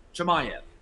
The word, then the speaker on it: Chimaev: Maybe we'll get in the next matchup, because the next one that I want to talk about is Khamzat Chimaev. Did I say it right? – Chimaev.